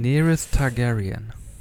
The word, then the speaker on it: Daenerys Targaryen: Daenerys Targaryen.